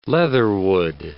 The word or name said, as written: leatherwood